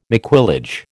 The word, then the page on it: maquillage: American English